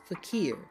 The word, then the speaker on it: faqir: The first word, F-A-Q-I-R, is pronounced fakir. Fakir, it is from Arabic. Then the second word, Q-I-G-O-N-G, is a word that we have borrowed from the Chinese language, and it is pronounced qigun, qigun.